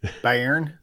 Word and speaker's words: Bayern: Bayern? – Bayern München.